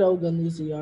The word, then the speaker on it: Ogunlusi: I feel like I've always said Ogunlusi art, though. – You've been saying it right.